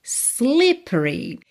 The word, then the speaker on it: slippery: Slippery. Esse é do meio não é pronunciado.